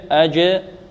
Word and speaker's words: أجر: طيب، فلهم أجر، أجر، أجر، هكذا نطقها، أج، أجر، أجر، فلهم أجر غير كذلك.